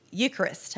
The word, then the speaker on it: Eucharist: notably the Catholic Eucharist.